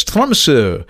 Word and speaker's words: Tromsø: And it kind of looks like Tromsø, but the O has a slash through it. And from what I understood at the time, the correct pronunciation of this Arctic village on the north shore of Norway is Tromsø.